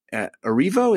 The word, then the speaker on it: Erivo: Cynthia and Erivo, is that how you pronounce that? – Yes.